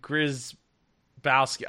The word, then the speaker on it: Grzybowski: and I'm interviewing Johnathan Grzybowski. I don't have the name up in front of me. Did I say it right? – Grzybowski. Grzybowski. Yeah, I got it right.